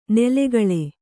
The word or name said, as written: ನೆಲೆಕೊಳ್